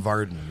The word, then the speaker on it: Vardon: How do you pronounce it? Varden. – Varden. Yep.